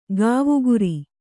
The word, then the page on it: ಕೋವಿಗಾರ: kōvigara